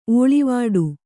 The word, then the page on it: ಓಳಿವಡೆ: ōḷivaḍe